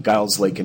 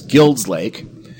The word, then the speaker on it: Guild's Lake: But everyone pronounces this as Guild's Lake, and it kind of looks like Guild because the word is G-U-I-L-D. – Okay. – So it looks like Guild, but it is named after Peter and Elizabeth Guild, who were the original settlers there.